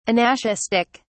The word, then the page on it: anaschistic: British English